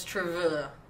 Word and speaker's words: TRVE: So, it's True. True. – Sure. – It just sounds like you're already drunk when you say it. True. – You pronounce it true.